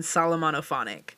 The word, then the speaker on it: Solomonophonic: I feel like it could also smell like a blend to the next song, Okay, by Wallows, Remy Wolf, and Solomonophone? It cuts off. – It's Okay by Wallows, Remy Wolf, and Solomonophonic, I believe is how you say his name.